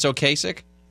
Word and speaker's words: Ocasek: And by the way, apparently that's how you say his name. – I knew for like my whole life it was Ocasek. – Yes. Do you know how old I was when I found out that it's Ocasek?